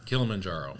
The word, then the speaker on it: Kilimanjaro: That's how I knew you climbed Mount Kilimanjaro. – Kilimanjaro. – That's it. Yeah. Whatever, if I pronounce it, go by him. Don't go by how I'm saying it.